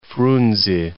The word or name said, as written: Frunze